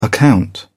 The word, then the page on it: account: British English